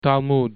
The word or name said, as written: talmud